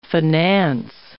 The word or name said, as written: finance